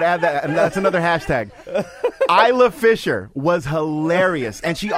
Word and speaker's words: Isla: Isla? Am I saying it right? – Yeah, no. You're saying it perfectly. He said it perfectly.